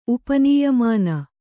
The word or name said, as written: ಉಪನೀಯಮಾನ